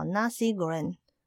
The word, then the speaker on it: nasi goreng: 就叫 nasi goreng。